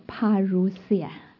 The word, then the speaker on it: Parousia: 那这个词在希腊文里面，怎么读还记得吗？读英文。 Parousia，这是希腊文，是 Parousia，耶稣基督的再来。